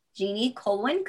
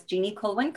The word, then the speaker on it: Jeannie Kulwin: So you can go to either Instagram or Facebook and it's at Jeannie Kulwin Coaching. I'll spell that because I know my name's a little hard. It's JeanNnie. KulwiN. So it's Jeannie Kulwin Coaching.